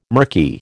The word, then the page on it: mirky: American English